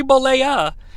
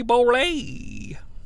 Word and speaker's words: Bollea: Again, harkening back to older shows, Mark Madden doesn't know how to pronounce Hulk Hogan's last name. It's Terry Bollea. Yes, yes, yes, Hogan chooses to pronounce the A, but Mark Madden always says Terry Bollea.